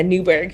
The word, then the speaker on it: Newburgh: So you grew up in Newburgh, New York. Is that how you say it? – Yeah, Newburgh.